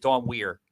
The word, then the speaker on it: Weir: Is it Dawn Weir or is it Dawn Weir? – Dawn Weir, like Bob Weir with the Grateful Dead, okay.